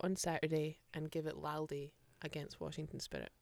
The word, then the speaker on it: Laldy: This week's word is Laldy. – Laldy? – Yeah. You can spell it L-A-L-D-Y or L-A-L-D-I-E.